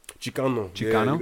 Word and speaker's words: Chicano: Explain what I believe it's pronounced Chicano. – Chicano. Yeah, you got it.